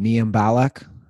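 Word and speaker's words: Mayim Bialik: Speaking of retreads, I mean, I see those commercials for, what's her name, Mia Balak? Is that how it's pronounced? – Yeah.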